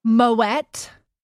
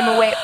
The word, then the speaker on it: Moët: I said Moët all the time. And it was like, Moët. – Moët. – Like, there's a T.